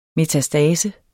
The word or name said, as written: metastase